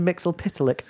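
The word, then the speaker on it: Mxyzptlk: Also, for some reason, I'm dressed like Mr. Mixlipitalik. Are you impressed I can say his name? Yes. I know. You put eyes in between every letter because obviously there's no consonants.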